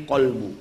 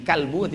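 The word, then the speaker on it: Qalbu (قلب): Tempat niat di qalbu. Qalbu artinya hati. Kalbu artinya anjing.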